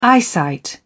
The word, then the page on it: eyesight: British English